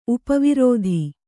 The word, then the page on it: ಉಪವೃತ್ತಿ: Kannada